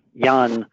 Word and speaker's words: Jan: Uh, Ian, uh, Jan that's how you pronounce it, Jan Nepomniachtchi.